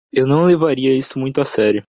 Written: levaria

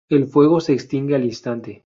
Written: instante

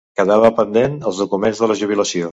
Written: documents

